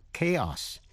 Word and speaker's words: chaos: có khi âm CH đọc là kh, như trong ba chữ ta đã học trong bài trước, đó là chaos, CHAOS là sự hỗn loạn, chamomile hay là chamomile là hoa cúc La Mã để trộn với trà, CHAMOMILE.